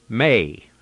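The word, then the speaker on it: may (μή): M-E. M-E. Now, in English, we'd pronounce it me, but in the Greek, it's pronounced may, M-A-Y.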